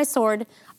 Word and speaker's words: sword: So I'm going to take out my sword. I always feel like I'm saying that wrong. Sword. – You said it. You're saying it perfectly.